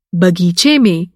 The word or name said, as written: बग़ीचा